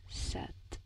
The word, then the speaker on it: sept: And deux, uh, trois, sept, sept. But it's spelled like S E P T.